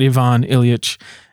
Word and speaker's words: Ivan Ilyich: I did read, however, a little novella, The Death of Ivan, I should pronounce it Ivan Ilyich, which is beautiful.